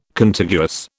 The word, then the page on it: Contiguous: British English